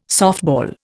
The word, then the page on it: softball: Indian